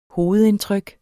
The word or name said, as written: hovedindtryk